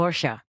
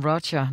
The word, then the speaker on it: Roger: Og navnet Roger på brasiliansk udtales ganske vist Roger, men flere her i Europa kalder ham Roger nok for nemhedens skyld. Vi er igen på jazzfestivalen Jazz Ahead, som jo er et mødested for bookere og musikere og spillesteder.